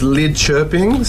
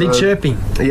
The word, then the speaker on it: Lidköping: It's pronounced Lidköping. – So, Lidköping. – Yeah, so the umlauts on the O is the sound. So Lidköping.